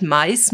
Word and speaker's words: MICE: Kannst du diesen, diese Begrifflichkeit Mais mal auflösen? Wie schreibt man das überhaupt? – Ich habe ja gerade schon gesagt, das ist wie vom englischen Wort Mäuse, also M-I-C-E wird das geschrieben.